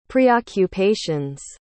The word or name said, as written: preoccupations